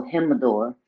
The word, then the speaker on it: Jimador: Because you got to, the J is not a J. It's like Jesus, so Jimador.